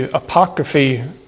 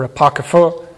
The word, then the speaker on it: apocrypha: It has the apocryphy, or apocrypha, depending on how you want to pronounce it,